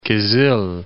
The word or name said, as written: Kyzyl